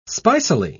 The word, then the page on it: spicily: British English